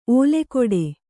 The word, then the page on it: ಓಲೆಗೊಡೆ: ōlegoḍe